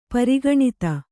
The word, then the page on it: ಪರಿಗಣಿತ: pari gaṇita